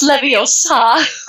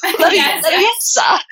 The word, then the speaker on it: Leviosa: It's Leviosa. Leviosa, not Leviosa.